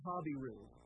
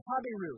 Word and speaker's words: Habiru: I like the ancient way of pronouncing that just because it has a sort of a, uh, a Lord of the Rings ring to it. Uh, Abram the Habiru. That's how they used to pronounce it.